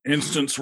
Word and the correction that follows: Anstis: Hey, that's not how you say it. What? – Ant King. What is it? What is his name again? – Anstis. – His name is Anstis, and then he fought things.